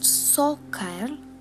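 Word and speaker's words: Saukerl: Sockle, pronounced sockerl, is for a male.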